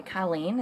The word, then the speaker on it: Colleen: And I said, "Colleen."